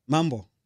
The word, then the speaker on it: Mambo: Mambo. That Mambo. – Means hello.